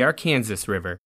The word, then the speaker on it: Arkansas: But here in Kansas, we say the Arkansas River. So both Kansas and Arkansas come from the same roots, but are pronounced differently today because of the languages they passed through.